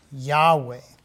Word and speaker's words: Yahweh: Since the writing, original writing of the King James in 1611, older copies of the Bible have come out, and a better understanding of how to pronounce this original word, which we have realized later is YHWEH, which is Yahweh.